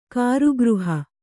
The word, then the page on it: ಕಾರಾಗೃಹ: kārāgřha